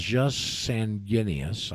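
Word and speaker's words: jus sanguinis: That there's two ways of, as you said, getting your hereditament, and one is called just Sanguinis, I believe is the way it's pronounced.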